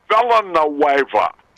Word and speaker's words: Villanueva: Yeah, I used to call Hector Villanueva Hector Venezuela. Steve Stone would tell me all the time, Harry, listen, it's Villanueva.